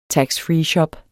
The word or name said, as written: taxfreeshop